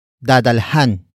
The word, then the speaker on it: dadalhan: The first word is dadalhan. Dadalhan. Dadalhan. Dadalhan is spelled as D, A, D, A, L, H, A, N.